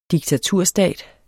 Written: diktaturstat

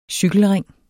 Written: cykelring

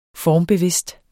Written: formbevidst

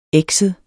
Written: ekset

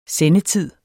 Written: sendetid

